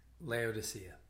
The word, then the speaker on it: Laodicea: Laodicea.